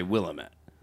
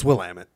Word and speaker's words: Willamette: I would say Willamette. – No, it's Willamette.